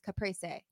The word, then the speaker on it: Caprese: I think it's Caprese. – Well, that's if it's you're not using the Italian pronunciation. It's Caprese. – Oh, it's Italian. – I'm sorry. – Whatever. – Caprese.